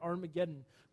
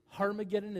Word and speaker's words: Harmageddon: And it says this battle is happening at Armageddon, which actually is Harmageddon, is the way to pronounce it. There's no, um, that's the way to pronounce it in the Greek.